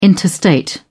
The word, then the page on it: interstate: British English